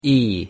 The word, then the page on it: e: English